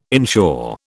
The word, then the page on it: inshore: British English